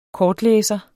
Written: kortlæser

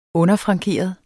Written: underfrankeret

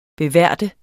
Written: beværte

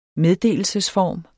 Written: meddelelsesform

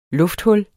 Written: lufthul

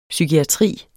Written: psykiatri

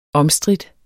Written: omstridt

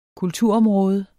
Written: kulturområde

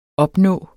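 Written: OPNÅEDE

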